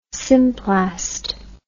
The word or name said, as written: symplast